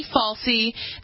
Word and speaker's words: Fauci: He's also ready to deliver presents around the world after receiving his COVID-19 booster shot, according to Dr. Anthony Fauci, the nation's leading infectious disease mass murderer.